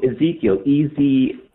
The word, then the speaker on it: Ezekiel: Maybe I'm pronouncing the name wrong. Ezekiel, easy. – Yeah, no, you're not pronouncing it wrong.